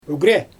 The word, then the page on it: ygrjæ: Xyzyl